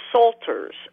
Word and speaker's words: psalter: And she did that by going to the Huntington Library there in L.A., which had acquired several medieval psalters. The word is psalter, and it comes from psalm, and it's spelled P-S-A-L-T-E-R.